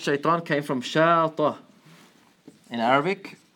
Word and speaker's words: shaitan: So, fa'lan, the word shaitan is on the same rhyme pattern. It's called nafsul wazn, right? Wazn, fa'lan. It rhymes with it. So, shaitan is not like shaitin.